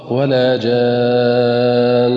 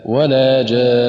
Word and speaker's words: ولا جان: وكذلك من الأخطاء الشائعة كلمة ولا جان في سورة الرحمن أيضا في حالة الوقف، فالبعض ينطقها خطأ: ولا جان بقلقلة النون. والصواب هكذا: ولا جان، ولا يرفع اللسان إلا بعد أن ينتهي من نطق النون.